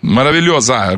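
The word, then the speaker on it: Maravilhosa: Я бы хотел сказать, зачем нужно какие-то шикарнейшие представления, открытия-закрытия, когда сам Рио, фантастический город, как поправьте мое произношение, как говорят, Сидаджи Маравильозу. – Маравильоза, город Маравильоза, женского.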